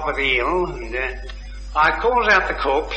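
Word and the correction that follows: corps: "Pardon me, that word is pronounced core."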